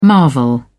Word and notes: marvel: British English

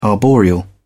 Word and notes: arboreal: British English

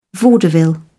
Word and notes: vaudeville: British English

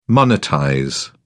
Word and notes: monetize: British English

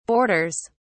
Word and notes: borders: British English

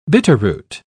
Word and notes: bitterroot: British English

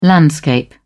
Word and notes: landscape: American English

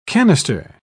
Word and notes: cannister: British English

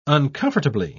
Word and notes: uncomfortably: British English

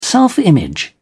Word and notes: self-image: British English

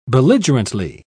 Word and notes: belligerently: British English

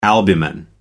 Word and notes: albumen: American English

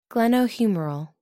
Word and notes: glenohumeral: American English

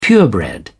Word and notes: purebred: British English

